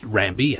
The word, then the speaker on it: Rabi: So on one of the islands we sailed to, a place called Rambi, pronounced Rambi and spelt R-A-B-I in the Fijian fashion, we've got a population of Micronesians.